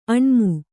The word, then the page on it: ಆಣ್ಮ: Kannada